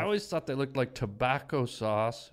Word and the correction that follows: Tabasco: Tabasco sauce mixed with beef gravy.